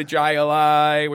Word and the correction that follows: Jai-Alai: which I'm probably messing that up. – Hi-Li. Hi-Li. Hi-Li.